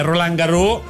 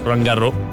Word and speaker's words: Roland Garros: Se fai Roland Garros sono 5, che non si legge la S. – Ah. – Roland Garros.